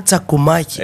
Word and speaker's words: τσακουμάκι: Εγώ νόμιζα το λένε τσακουμάκι. – Έτσι, βέβαια. – Και ένα ου ανάμεσα.